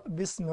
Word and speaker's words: باسم: لا ما تنطقش الألف. اقرأ باسم ربك. باسم. بس. – اقرأ باسم. – باسم لا. باسم. – اقرأ باسم ربك. – اقرأ باسم. اقرأ باسم ربك. – اقرأ باسمي. – مش باسمي يا ولد. مفيش ألف هنا.